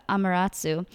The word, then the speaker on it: Amaterasu: Many tried to coax her out, but in the end, it was Ame no Zum. Oh my gosh, sorry, I totally butchered that. Amaterasu, who lured her out with dance and music and brought light and warmth back to the land.